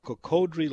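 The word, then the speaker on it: Cocodrie: Up there, they call it Cocodry. Down south, it's Cocodry. Never could figure that out. But we'll pronounce it where it's from. Cocodry Lake up at Forest Hill.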